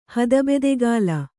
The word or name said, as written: ಹದಬೆದೆಗಾಲ